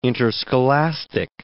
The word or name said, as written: interscholastic